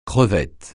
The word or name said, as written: crevette